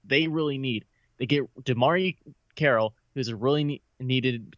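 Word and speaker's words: DeMarre: This is that small forward position, getting DeMar Carroll. Did I say that right? DeMari Carroll. – Just say DeMari. – DeMari. There we go.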